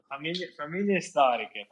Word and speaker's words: Famiglia Storica: And you were one of the founding members of, how do I pronounce this, Famiglia Storica? – Bravo, bravo, bravo. Yes, Famiglia Storica.